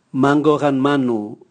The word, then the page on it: mangao gan manu: Raga